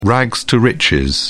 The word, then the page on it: rags to riches: British English